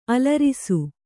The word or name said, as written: ಆಳರಸ